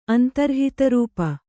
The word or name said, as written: ಅಂತರ್ಹಿತರೂಪ